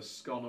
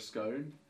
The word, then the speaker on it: scone: In England, there's like a bun that you can buy, a sweet bun called a scone or a scone. Nobody knows. So how do you pronounce it? Scone that rhymes with gone, scone that rhymes with cone.